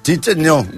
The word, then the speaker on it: Cicinho: Cicinho, que a no s'ha de pronunciar com és? – Chicheño. – No, Chicheño. No, com el Barragán. – No. – Chicheño. Chicheño. Chicheño. Alú, Chicheño.